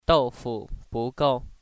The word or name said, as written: ou